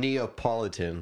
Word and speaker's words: Neapolitan: Or is that ice cream? Neapolitan. – That's what it is.